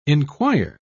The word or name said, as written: enquire